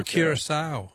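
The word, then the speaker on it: Curaçao: And I don't know if I'm pronouncing it right, but I thought it was Curacao. – Oh, Curacao, yeah. No, that's very good. I'm sure you're pronouncing it correctly, but that's not...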